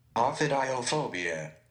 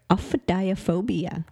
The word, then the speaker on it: Ophidiophobia: Ophidiophobia. – Ophidiophobia.